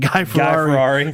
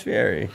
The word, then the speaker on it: Fieri: He said Guy Ferrari. – Guy Ferrari. I honestly thought before I knew what his name was, that's how I pronounced it. Guy Ferrari. Like, Guy Ferrari. – Yeah, that's Fieri. Whatever.